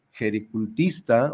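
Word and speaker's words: gericultista: es terapeuta domicilio jericultista, ahora sí lo dije bien, jericultista, porque andaba acoplándole otras letras.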